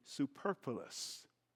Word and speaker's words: superfluous: That's because Ken is totally superfluous. That's a big word. I had to syllabize it, build it up in syllables to say it right.